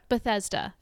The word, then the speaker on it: Bethesda: I'm going to maybe butcher this pronunciation. – Cool. – The Bethesda? – Bethesda? – Bethesda. Thank you.